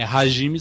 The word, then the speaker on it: Hajime: Isso, aí tinha um rival dele, que era o Hajime Saito. Aí pra gente, o nosso som é, é som de R, mas é com H. É Hajime Saito.